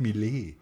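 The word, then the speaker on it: Miele: Da würde mit Miles auch super funktionieren, wenn jemand sagt, ich habe mir dann ein Miles-Auto genommen, dann so, wie sprichst du das denn aus? Das heißt Millet. – Millet.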